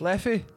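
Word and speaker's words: Leffe: It's L-E-F-F-E-F-E. – Leffy.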